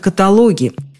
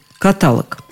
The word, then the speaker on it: каталог: Ударение неправильное без всяких оговорок, без всяких скидок, просто неправильное и все. Слово пришло к нам из французского языка – каталог, а туда, в свою очередь, из греческого – каталогос – список. Замечу, даже в толковом словаре Даля ударение именно на последнем слоге